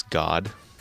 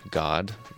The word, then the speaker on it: Gad: You know, tribe of Gad is God, pronounced God, apparently.